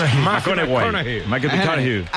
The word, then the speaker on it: McConaughey: Hay que ir corrigiendo la pronunciación. – McConaughey.